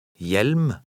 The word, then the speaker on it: Hjelm: When a word begins with an HJ combination, the H is silent. Try to read number 11. – Hjelpe. – 12. – Hjelm.